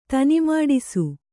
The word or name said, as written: ತನಿಮಾಡಿಸು